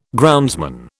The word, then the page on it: groundsman: British English